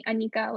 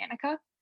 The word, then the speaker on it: Anika: Like I personally had an experience very similar to Tanvi's where my parents have always called me Anika, like that's the correct pronunciation of my name. But whenever I was in situations outside of my family and talking to friends or at school, I would always be Annika.